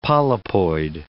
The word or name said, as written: polypoid